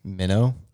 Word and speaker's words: meno: Meno? It's a verb. Our, a our transliteration would be M E N O with a long O, meno.